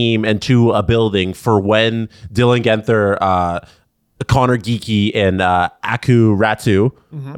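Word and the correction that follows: Aatu Räty: Is it Aku or Atu? – Atu Ratu. Yeah,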